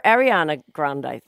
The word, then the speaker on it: Ariana Grande: The Ariana Grande thing.